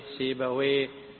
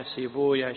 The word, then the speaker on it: سيبويه: قلنا كل اسم ينتهي بواو وياء وهاء فاللغويون يقولون ويه، إسحاق بن راهويه، نفطويه، سيبويه، شبويه. أما المحدثون فيقولون لا، يكره نطق الاسم بويه. وذكروا أن ويه وصف النوصاف أو اسم من أسماء إبليس بالفارسية، وإنما يقال راهويا سيبويا شبويا.